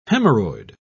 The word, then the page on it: haemorrhoid: British English